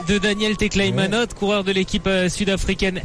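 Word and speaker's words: Teklehaimanot: Vous l'avez entendu, donc il y a trois hommes en tête, donc dont l'Érythréen Tekleimanot. On a appris à le prononcer, c'était pas facile de début du tour, mais on commence à l'avoir bien en bouche. Tekleimanot qui est passé tout à l'heure au sommet de la petite côte, la première...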